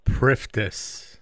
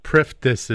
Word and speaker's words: Priftis: Well, some some will uh, keep the SS silent, but Priftis is the Canadian way of saying it.